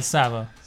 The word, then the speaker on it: Tsavo: I think this particular story, it's of the, I'm not sure how to pronounce this, but the, Tsavo Man-Eaters. – Yeah, Tsavo. – The Tsavo Man-Eaters.